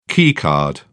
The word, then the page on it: keycard: British English